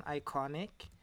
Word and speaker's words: IKONICK: If you go in on Iconic, it's spelled IKONICK.